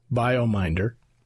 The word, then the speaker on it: Biomindr: It's spelled B-I-O-M-I-N-D-R, BioMinder.